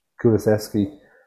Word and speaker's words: Kulusevski: How'd you pronounce it? – Kulusevski.